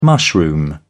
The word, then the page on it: mushroom: British English